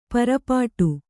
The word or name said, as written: ಪರಪಾಟು